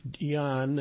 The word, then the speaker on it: Jan: With a wealth of courage, brain power, and musical talent, the one thing local music teacher and musician, Jan, that's pronounced Y-A-H-N, Johansson, could ask from The Wizard of Oz is a new heart.